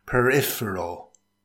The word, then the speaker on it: peripheral: It consists of four syllables. Stress the second syllable. It's pronounced peripheral.